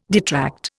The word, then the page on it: detract: Indian